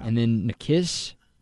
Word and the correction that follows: NCIS: is that how it's pronounced? – NCIS.